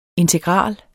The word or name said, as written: integral